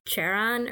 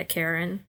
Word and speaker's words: Charon: In the notebook, Maria writes down next to his name, Charon or Karen or whatever the Greek barge keeper. – Karen? – Yeah, Karen. That stupid H is silent, so anyway, she writes that down, question mark, next to his name.